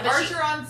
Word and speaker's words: Archeron: But Archeron sounds correct. – I think that's right. – That's how I would say it. That's how you say it.